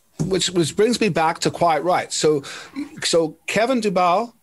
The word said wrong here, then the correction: Dubrow: is that how you pronounce it? Is it Dubow? – Dubrow. – So Dubrow.